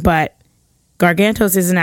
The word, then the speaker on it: Gargantos: is actually going to be named Gargantos. Am I saying that right? – Yeah, you are.